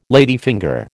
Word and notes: ladyfinger: American English

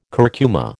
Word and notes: curcuma: American English

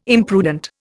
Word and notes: imprudent: Indian

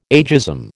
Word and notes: ageism: American English

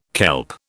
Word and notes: kelp: British English

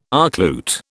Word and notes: archlute: British English

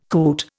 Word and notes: coot: Indian

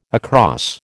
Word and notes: across: American English

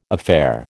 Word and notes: affair: American English

